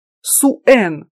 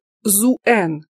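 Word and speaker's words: Suen: Другое имя Нанны, Син, происходит от Суэн, которое может произноситься как Зуэн, владыка знаний.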